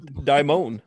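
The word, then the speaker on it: daemon: Daimon.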